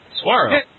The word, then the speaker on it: saguaro: I think Suharo would be great as a deadpan character. – Oh, and I went to... Hey, I'm an eye expert here. I've been to Arizona. It's Suharo. – Suharo.